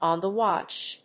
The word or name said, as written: On the watch